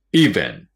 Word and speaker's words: event: event。まあ、日本語でもイベントって言いますが、最初にアクセントがあります。気をつけてください。